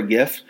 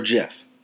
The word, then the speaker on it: GIF: How do you pronounce GIF? Is it GIF or JIF? Because if you say JIF to me, I'm kicking you dead in the fucking neck.